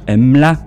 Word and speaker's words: Mlah: Et deux ans auparavant, avant 1990, donc 1988, ils sortent l'album Mla, mais ça s'écrit M-L-A-H. Enfin, je sais pas, je le prononce bien, mais c'est Mla. – Mla. – Oui. – Comme la mlasse.